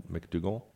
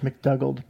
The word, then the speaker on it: McDougald: And in addition to him, when you move away from the linebackers and you look at safety, we brought in Bradley McDougald. I think is how you pronounce his last name. – I thought it was McDougald, but yeah.